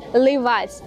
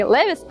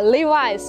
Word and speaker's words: Levi's: Левисы наверняка подумали вы, а вот и нет, правильно говорить Levi's. Levi's, не Levi's, а Levi's.